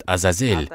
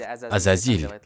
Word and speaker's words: Азазель: В предыдущих писаниях и в некоторых риваятах, написанных на языке сирияния, арамейском, его называют Азазель. У нас это произносят как Азазиль, шайтан, то есть от слова Азазель.